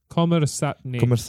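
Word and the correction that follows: Kommersant (Коммерсантъ): Kommersant. – Okay, there we go.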